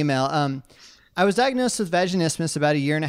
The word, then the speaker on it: vaginismus: This concerns a condition called vaginismus. Am I saying that right? All right, cool. – You are vaginismus.